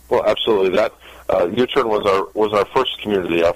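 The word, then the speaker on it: YouTern: So, well, I know you, you're also the CEO and founder of another company, if I'm saying this right, U-Turn, which is T-E-R-N, not U-Turn like making a U-Turn, but U, Y-O-U-T-E-R-N.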